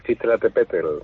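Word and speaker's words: Citlaltépetl: a ver si lo digo bien, Citlapel, Pico de Orizaba, algo así, ¿no? ¿No lo he dicho bien o lo he dicho mal? – Sí, bueno, Citlatepetl.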